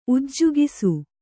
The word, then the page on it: ಉಜ್ಜುಗಿಸು: ujjugisu